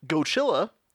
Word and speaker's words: Godzilla: And Barugon is renamed to Godzilla, which is pronounced Gochilla in German.